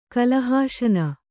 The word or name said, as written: ಕಲಹಾಶನ